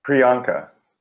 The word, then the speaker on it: Priyanka: Priyanka.